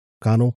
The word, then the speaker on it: Conall: I've never seen it spelled this way. C-O-N-A-L-L. Connell. Connell. I think that's it.